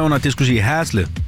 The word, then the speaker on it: Haslev: Udtalte jeg det rigtigt nok, Haslev? – Hej, Haslev. – Ja, ja, okay.